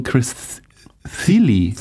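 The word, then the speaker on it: Chris Thile: Ich habe in euch gelernt, es wird ausgesprochen Chris Thiele. – Thiele. – Ich dachte auch, es wäre Chris Thiel, wurde in diesem Podcast auch schon mal erwähnt, falsch ausgesprochen, sorry dafür.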